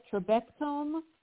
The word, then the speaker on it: Trabectome: But I wanted to mention Dr. Okeke's book, The Building Blocks, and I don't know if I'm pronouncing this right, Trabectome Surgery, is that how you say it? – Yes, that's correct.